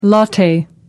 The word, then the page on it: latte: American English